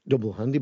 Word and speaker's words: Dubl Handi: Double Handy is pronounced Double Handy, but it's spelled D-U-B-L-H-A-N-D-I.